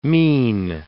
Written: mien

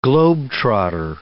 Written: globe-trotter

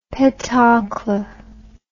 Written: petoncle

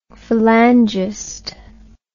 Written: phalangist

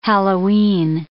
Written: Hallowe'en

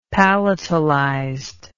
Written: palatalized

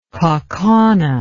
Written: kaukauna

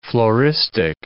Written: floristic

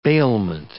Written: bailment

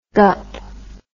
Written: gup

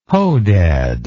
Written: hodad

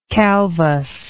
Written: calvus

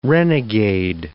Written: renegade

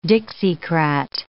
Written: Dixiecrat

